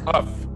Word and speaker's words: Hough: This was The Legend of Hell House, 1973, starring Pamela Franklin, Roddy McDowall, Clive Revel, and directed by John Huff. Huck? How do you pronounce it? Huck? – Huff. Huff. – Huff. Huff. Yeah. – Like we're Don puffing, Huff. Puff your house down.